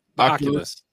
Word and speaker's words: Oculus: The Oculus.